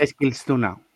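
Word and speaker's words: Eskilstuna: o como se pronuncia eso. – Esclistuna.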